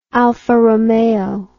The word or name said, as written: alfa romeo